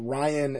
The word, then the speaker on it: Ryan: Well, it's kind of weird that Brian, like, is normal B-R-I-A-N, but Ryan is R-Y-A-N. Because my middle name's Ryan. – It's not R-I-A-N.